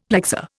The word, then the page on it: plexor: Indian